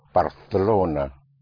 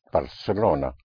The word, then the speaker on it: Barcelona: There's a slight difference in pronunciation with so-called Castilian Spanish and the pronunciation in parts of Spain, where the C in front of an E or I is pronounced like a TH in English, like in Barcelona. Well, that's how you will pronounce it in Castilian Spanish in parts of Spain, but everywhere else it will be pronounced Barcelona. like an S.